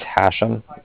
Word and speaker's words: Hashem: My name is Hashem. That's H-A-S-H-E-M, as in mother at the end. – A-S-H-E-N, okay.